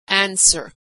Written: answer

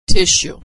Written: tissue

